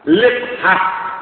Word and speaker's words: لقحة: لقحة بكسر اللام وإسكان القاف لقحة اللام مكسورة والقاف